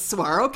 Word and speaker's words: saguaro: Saguaro.